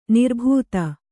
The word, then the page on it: ನಿರ್ಭೂತ: nirbhūta